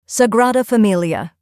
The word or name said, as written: Sagrada Familia